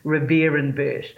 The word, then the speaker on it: Rivierenbuurt: but the pronunciation is Rivierenbuurt.